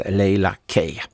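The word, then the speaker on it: Leila K: Men hade han lyssnat på hur Kai uttalade hennes namn innan låten kom igång, då kanske han också hade sagt korrekt Laila Kay.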